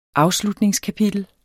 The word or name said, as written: AFSLUTNINGSKAPITEL